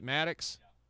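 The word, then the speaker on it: Maddoux: It's a property owned by Brad and Shelley Maddox. I wish someone would tell me how to pronounce this last name. It's M-A-D-D-O-U-X. Is it Maddox? Okay, Maddox. All right, we're going to go with Maddox.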